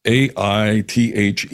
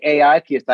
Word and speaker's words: AItheist: because it is AItheist, did I pronounce it right? – You did. I usually say the AItheist.